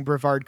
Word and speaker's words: Brevard: As far as I know, you're pronouncing Brevard correctly.